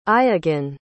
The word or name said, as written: iagan